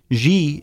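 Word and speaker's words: ji (汁): The word has died out of modern Hokkien, but the syllable chup, pronounced ji in Mandarin, still means sauce in many Chinese dialects.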